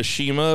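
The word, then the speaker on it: Cima: We had a Shima appearance. It was Shima, which I think I'm pronouncing right. It's C-I-M-A, but it's pronounced like you would say Shelly.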